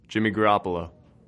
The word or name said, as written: Garoppolo